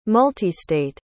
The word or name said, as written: multistate